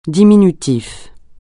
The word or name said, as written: diminutif